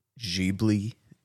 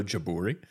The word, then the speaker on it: Ghibli: Andrew, did you know that Studio Ghibli is not pronounced Studio Ghibli in Japanese? Mm-mm. And it's also not pronounced Studio Ghibli in Japanese. – I didn't know any of that. – It's actually pronounced Studio Jaburi, which is one of the most insane things I've ever heard in my life.